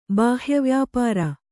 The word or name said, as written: ಬಾಹ್ಯವ್ಯಾಪಾರ